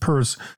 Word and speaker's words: Peirce: And please don't send me emails defending Charles Peirce, which I think was actually pronounced Peirce, because he's the guy who invents the phrase pragmatism.